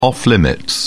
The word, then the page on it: off limits: British English